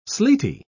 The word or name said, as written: sleety